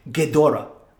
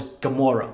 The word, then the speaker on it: Ghidorah: And then there was, and I don't, still don't know if this name, until this movie, I realized I was saying it wrong, Ghidorah. I thought it was Gamora, like Gimera, or Gamora, kind of like from Guardians of the Galaxy.